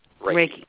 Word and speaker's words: Reiki: Is it Reiki or Reiki or how do you say that? – Reiki. Yep.